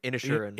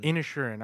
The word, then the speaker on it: Inisherin: Inisherin. – Inisherin.